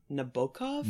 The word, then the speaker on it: Nabokov: I think it's correctly pronounced Nabokov.